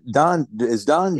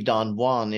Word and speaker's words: Don Juan: It's supposed to be Don Juan. It rhymes with that's just the way they pronounced it. It would normally be Don Juan if you were in Spanish, but Byron's poem is pronounced Don Juan because.